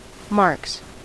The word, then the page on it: marks: American English